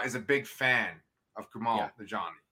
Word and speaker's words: Kumail Nanjiani: They were going to actually put Kumail Nanjiani.